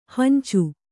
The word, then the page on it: ಹಂಚು: kannada